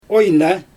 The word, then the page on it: ojna: Xyzyl